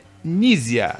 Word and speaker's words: Knizia: eu vou te mostrar como é que pronuncia é Nízia. – Nízia. – Nízia, não se fala o K, é Nízia.